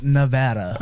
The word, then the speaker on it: Nevada: And it's pronounced Nevada.